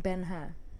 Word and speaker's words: Ben-Hur: Have you seen Ben-Hur? – No.